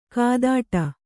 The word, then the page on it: ಕೈತಟ್ಟು: kai taṭṭu